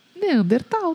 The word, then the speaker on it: Neandertal: Neandertal? Como é que fala? – Neandertal, tá certo.